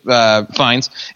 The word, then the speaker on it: Fiennes: Joseph Fiennes, or however you say his name, Fiennes. – It's not that, even though it looks like it should be, but you're right.